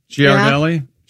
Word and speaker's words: Ghirardelli: Oh, is it pronounced Ghirardelli? – Ghirardelli? Yeah.